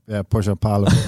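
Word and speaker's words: Pohjanpalo: Joel Pojanpalo. – Yeah, Pojanpalo.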